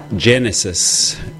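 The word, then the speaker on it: Génesis: Genesis, with the accent on the first E.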